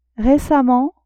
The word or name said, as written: récemment